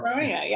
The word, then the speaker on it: Aronia: Am I pronouncing, is it Aronia? – Yeah, or? – Aronia.